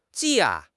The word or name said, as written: ji4